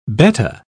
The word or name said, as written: betta